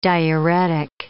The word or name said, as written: diuretic